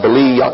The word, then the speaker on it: Belial: If you give the Hebrew letters their equivalent, you have B-E-L-I-Y-Y, you got to double the Y, A apostrophe A-L, and you would pronounce it Belial, Belial. But it just generally transliterates across Belial, B-E-L-I-A-L.